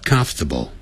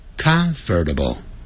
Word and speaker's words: comfortable: It's old, but comfortable. 变念成 Comfortable 相信我